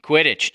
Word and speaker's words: Quidditch: 2100, my bet's on Kittich. Is that how you pronounce it? Quidditch? – Quidditch. Come on.